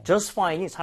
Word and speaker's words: just fine: 이런 느낌으로 just fine 했는데, 얘를 빠르게 발음을 하면 이 중간에 샌드위치가 된 T 사운드는 거의 나오지가 않을 거예요.